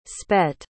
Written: spet

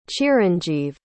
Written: chiranjeev

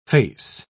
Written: face

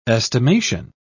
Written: estimation

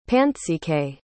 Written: Pantxike

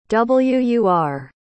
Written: wur

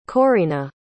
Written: correena

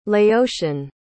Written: laotian

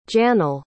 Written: janyl

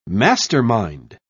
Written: mastermind

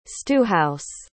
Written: stewhouse

